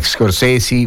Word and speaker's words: Scorsese: Scorsese, la E finale si legge I, Scorsese, Versace, lo so, è così, che ci possiamo fare.